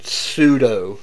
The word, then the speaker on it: pseudo: That's pseudo. No, you don't. It's like pneumonia. You don't pronounce the P.